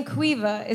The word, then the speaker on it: Caoimhe: Like, my first ever flatmate was an Irish girl named Cuiva, and that name Cuiva is spelled C-A-O-I-M-H-E.